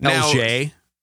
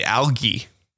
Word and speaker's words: algae: Am I saying that right? It's not algae? – Nah, I've heard people say algae.